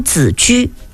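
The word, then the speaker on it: 子车: 方赫连黄府尉迟公羊澹台公冶宗正濮阳淳于单于太叔申屠公孙仲孙轩辕令狐钟离宇文长孙慕容先于吕丘司徒司空齐官司寇长都子居。这个子居两个字哈，一个是孩子的子，一个是车那个字的另一个读音居，我们象棋当中居那个字啊，子居。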